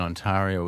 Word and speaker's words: Ontario: Question today, on this day in 1983, the town and village of Niagara Falls in Ontario was incorporated as a city. Ontario, did I say that wrong? No.